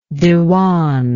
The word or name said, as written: dewan